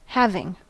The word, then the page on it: having: American English